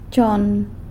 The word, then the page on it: chon: sanlucasquiavini